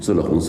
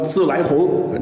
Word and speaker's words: 自来红: 京式月饼里当然最多的就是这种自了红自了白。老北京啊，看你是不是北京人，就看你怎么念这个三个字，是吧？老北京一定叫自了红自了白，读清音。如果你说自来红，那就不是老北京人。